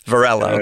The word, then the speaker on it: Varela: Well, Carlos, it's Carlos Varela. – Varela, okay. – That's his pronunciation.